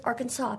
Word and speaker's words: Arkansas: If you are from the state and you pronounce it correctly, it is Arkansas, baby.